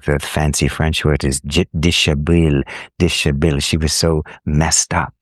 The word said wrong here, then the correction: déshabillé: And I, déshabillé, yeah.